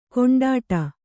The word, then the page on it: ಕೊಂಡಾಟ: koṇḍāṭa